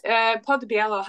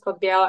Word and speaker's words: Podbiello: So my American is Podbiello and my Polish is different, but it doesn't exist in English because we have special letters in Polish. So it's Podbiello.